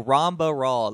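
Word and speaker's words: Ramba Ral: Rambal Rawl. Oh, Ramba Rawl. That's why. – Ramba Rawl. – Ramba Rawl. I've been saying his name wrong this whole time.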